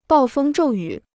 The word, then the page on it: 暴风骤雨: bào fēng zhòu yǔ